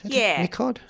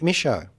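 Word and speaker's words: Michôd: It's directed by David Michaud. – Michaud. – There's a picture here saying how to pronounce it. Actually, Siri got it. Michaud. – Michaud.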